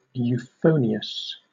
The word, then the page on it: euphonious: British English